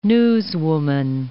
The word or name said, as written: newswoman